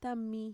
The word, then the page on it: tami: Mixtec